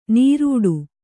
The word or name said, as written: ನೀರುಡು